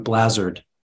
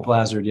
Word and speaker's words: Bleazard: Bleazard. – Bleazard, not how I say it. Very good. – So it rhymes with hazard. – Derek Bleazard. Very cool. – Bleazard.